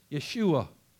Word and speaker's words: Yeshua: You know what the Hebrew word, how they pronounce Jesus? Yeshua.